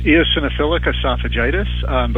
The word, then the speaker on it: eosinophilic esophagitis: It's eosinophilic esophagitis, but we can say EOE.